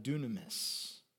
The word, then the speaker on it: dunamis: Above anything else, the Greek word that we often translate as miracle is dunamis, which sounds like the word dynamite, because that connection was on purpose.